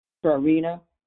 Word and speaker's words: Trarina: Teachers and children were not able to pronounce my name, Trarina and my last name was Catchings.